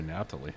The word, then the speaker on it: Naphtali: How do you pronounce it in ritual? We say Naphtali.